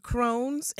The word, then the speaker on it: Crohn's: Now, we're going to talk today about inflammatory bowel disease and Crohn's and ulcerative colitis.